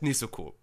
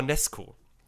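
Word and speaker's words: Nezuko: Ja, Nesuko heißt halt Nesuko. So. Weil ZU wird ja immer mit Su ausgesprochen.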